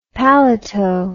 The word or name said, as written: palato